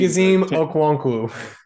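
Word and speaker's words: Chigoziem Okonkwo: Chigazim Okwonkwo.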